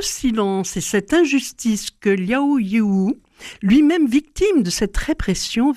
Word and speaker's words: Liao Yiwu: Liao Yiwu, voilà.